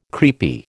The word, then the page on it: creepy: American English